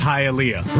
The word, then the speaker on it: Hialeah: It's pronounced Hialeah.